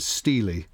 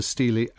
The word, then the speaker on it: stele: the stele, as I gather it's pronounced, the stele